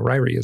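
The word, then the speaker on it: Ririe: In Ryrie, Idaho. – Ryrie, yeah. – Yeah, yeah. – So I lived in Idaho. Ryrie is how it's pronounced, yeah.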